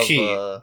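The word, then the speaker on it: key (χ): It's pronounced key. – Yeah, because it's like it's not an X. It's like the symbol key, which looks like kind of like an X.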